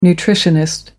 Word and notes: nutritionist: American English

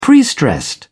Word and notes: prestressed: British English

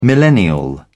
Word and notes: millennial: British English

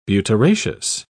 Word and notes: butyraceous: British English

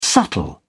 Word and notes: subtle: British English

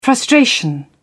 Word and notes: frustration: British English

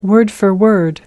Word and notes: word for word: American English

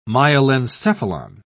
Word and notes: myelencephalon: British English

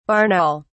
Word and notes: barn owl: British English

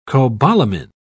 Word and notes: cobalamine: British English

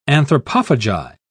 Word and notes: anthropophagi: British English

